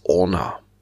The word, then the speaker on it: Orna: Das Spiel heißt Orna. O-R-N-A. Nur kurz und knapp, zwei Silben. Orna.